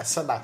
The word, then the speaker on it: Sanaa: Oh, também, acho que a pronúncia correta é Sanaa, se eu não me engano, a pronúncia, Sanaa.